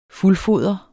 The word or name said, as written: fuldfoder